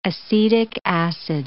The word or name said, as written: acetic acid